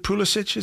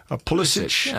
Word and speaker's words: Pulisic: Is it Pulisic? Is that how you say his name? Pulisic. – Yeah, I believe so.